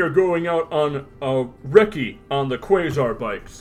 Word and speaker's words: recce: It's pronounced recce, which is a very British military term that is a short slang for reconnaissance.